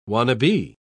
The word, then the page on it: wannabee: British English